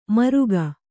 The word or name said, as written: ಮೂರ್ಕ